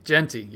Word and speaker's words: Jeanty: Ashton Jeanty did I say that right? – Boise State. – Jeanty. You're right. Jeanty. Uh, I knew it didn't sound right.